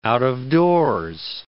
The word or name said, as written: out-of-doors